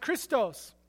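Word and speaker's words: Christos (Χριστός): It has an X. It's actually pronounced Christos.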